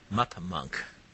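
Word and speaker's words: MupMonk: Mup Monk.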